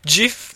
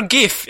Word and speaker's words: Jif: A full bar of Jif peanut butter, or GIF if you want to say it wrong. – I actually think it is Jif.